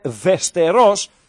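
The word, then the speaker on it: Västerås: Η ομάδα λέγεται Βεστερός. Τονίζεται στη Λίγουσα, να μαθαίνουμε και πέντε πράγματα. Έργριτε Βεστερός 0-0.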